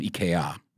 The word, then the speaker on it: IKEA: All my furniture is from IKEA.